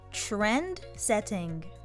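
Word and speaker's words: trendsetting: Yes, sounds good トレンドの TR の発音がポイントです。もう一度。 – Trend setting.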